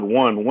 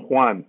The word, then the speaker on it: Juan: They would say Juan, Juan. I said, no, it's not one. One is a number. I'm Juan.